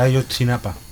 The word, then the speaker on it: Ayotzinapa: Bueno, y a nivel internacional, México, con la tragedia o con la desaparición todavía de los 43 estudiantes de Ayotzinapa. – Muy bien pronunciado. Gracias por el rescate. – Por el náhuatl, por su pronunciación en náhuatl.